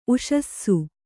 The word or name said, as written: ಊಸಿಸು